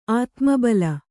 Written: ಆತ್ಮಬಲ

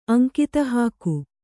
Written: ಅಂಕಿತಹಾಕು

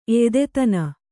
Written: ಎಯ್ದೆತನ

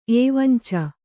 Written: ಏವಂಚ

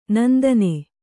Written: ನಂದನೆ

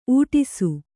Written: ಊಡಿಸು

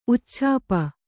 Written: ಉಚ್ಛಾಪ

